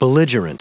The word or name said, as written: belligerent